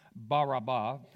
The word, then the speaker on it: Barabbas: And they said, "Barabbas," which is pronounced Baraba, if you care.